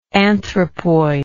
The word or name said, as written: anthropoidal